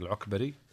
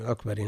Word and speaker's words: العكبري: تنطق العكبري. – نعم العكبري.